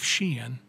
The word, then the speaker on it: Sheehan: Facebook page is Sheehan Marketing Strategies. And my Twitter is Jeff Sheehan.